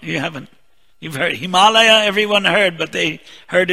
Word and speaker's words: Himalaya: Himalaya. They call it Himalayas because they can't pronounce it properly.